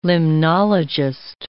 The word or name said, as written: limnologist